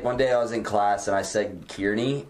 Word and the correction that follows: Kearney: and she's like, you're not from around here, are you? And I was like, no, why? She's like, it's pronounced Kearney.